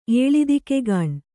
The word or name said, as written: ಏಳಿದಿಕೆಗಾಣ್